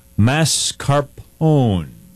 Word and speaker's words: mascarpone: So we're going to talk about the cookies and cream mascarpone.